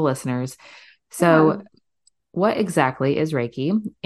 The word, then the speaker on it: Reiki: Is it Reiki or Reiki? – It's Reiki. – Reiki, that's what I thought.